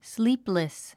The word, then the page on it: sleepless: slíːpləs; スリープレス